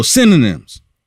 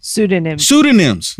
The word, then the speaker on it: pseudonyms: Joe Biden used multiple synonyms. Multiple. Not one, not two, but three that we are aware of. – Pseudonyms.